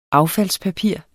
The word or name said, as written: affaldspapir